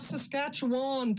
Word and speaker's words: Saskatchewan: You said it right. Not Saskatchewan, people. Take it from me. I know somebody who was born there, Glenda, and you do not say Saskatchewan any more than you say Newfoundland.